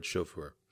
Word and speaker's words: chauffeur: James Brown still hasn't been buried, according to his former chauffeur. You heard that right. That is exactly how it sounded, including my mispronunciation of the word chauffeur, like I just did again. Chauffeur. Anyway,